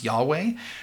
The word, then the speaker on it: Yahweh: Additionally, instead of using the proper name of God, YHWH, which we often pronounce Yahweh, Jewish readers of Scripture will substitute the name Adonai, meaning Lord, for the divine name.